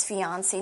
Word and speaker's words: fiancé: so it's F-I-A-N-C-E, with just one E. – But the pronunciation is the same for male or female. Fiancé, fiancé.